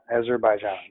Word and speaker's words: Azerbaijan: All right, so let's talk about let me make sure I'm saying this right. Azerbaijan, Brajan, right? – Azerbaijan, yeah, that's it. – Azerbaijan, yeah.